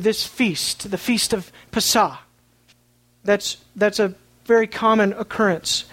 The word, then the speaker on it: Pesah: And in order to help us remove any understanding that we have of it, I'm just going to pronounce the Hebrew word rather than the English word, Passover. Pesah. Pesah.